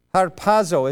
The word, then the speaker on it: harpazo: Now, the Greek word from which caught up comes is the word harpazo. Is that how you pronounce that, Greek scholars? I got one nod, two nods. Any more nods? I got two nods out of two nods, so must be okay.